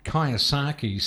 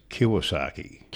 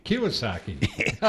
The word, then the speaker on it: Kiyosaki: Now, we've got a listener who said his Facebook page featured an article by Robert Kiyosaki stating savers are losers. What's your opinion on that? – That's actually pronounced Kiyosaki. – Kiyosaki.